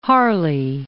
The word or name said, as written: Harley